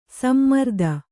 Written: ಸಮಾರಾಧನೆ

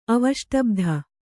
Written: ಅವಷ್ಟಬ್ಧ

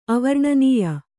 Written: ಅವರ್ಣನೀಯ

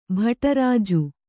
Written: ಭಟರಾಜು

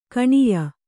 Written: ಕನೀಯ

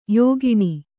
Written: ಯೋಗಿನಿ

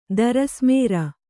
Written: ದರಸ್ಮೇರ

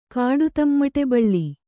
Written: ಕಾಡುತಮ್ಮಟೆಬಳ್ಳಿ

